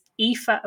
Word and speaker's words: Aoife: It's Aoife O'Brien, and it's spelled A-O-I-F-E, O'Brien, O'Brien.